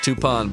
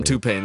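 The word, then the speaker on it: Toupane: And I know it's pronounced Toupon, but I'm going to call him Toupain.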